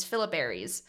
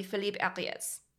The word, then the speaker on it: Philippe Ariès: Uh, so the American version of this is Philip Aries. Philip Aries. Uh, the French version is probably Philippe Ariès.